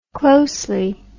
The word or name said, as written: closely